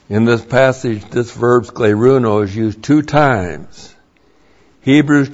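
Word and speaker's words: scleruno (σκληρύνω): And then there's another verb for teaching the scar tissue of the heart, and that is the verb scleruno. Scleruno, S-K-L, long E, R-U-N, long O, scleruno.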